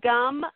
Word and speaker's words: Gam: Om Gam, like gum chewing your gum. – Oh, gum. Okay. – Om Gam Gana. Gana. G-A-N-A.